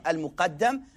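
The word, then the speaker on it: Al-muqaddim (المقدم): Al-muqaddim. I said mutaqaddim. Al-muqaddim wal-mu'akhir. That's the proper way to say the name.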